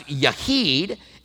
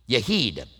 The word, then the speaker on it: Yachid: That's Yahid, and that is spelled Y-A-C-H-I-D, Yahid.